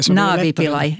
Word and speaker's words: Navi Pillay: Var det ungefär rätt? – Och förkortningen var Navi Pillay. – Navi Pillay, ja.